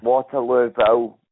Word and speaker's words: Waterlooville: So Waterloo Hill. Is it? – Waterlooville, it's spelled WATRLOOVILLE, Waterlooville.